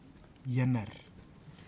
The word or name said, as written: ianar